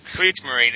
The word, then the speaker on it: Kriegsmarine: Sorry, I don't know how to pronounce Kriegsmarine. – Kriegsmarine, exactly right. Yes.